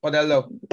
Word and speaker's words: Padello: Padello.